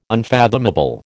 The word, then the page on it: unfathomable: American English